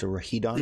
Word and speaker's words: Rhydon: um, is a Gen 1 classic, and it's Rhydon. Woo! – It's pronounced Rahidon? – Yeah.